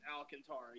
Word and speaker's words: Alcantara: So it's Alcantara. – It's Alcantara. Yes.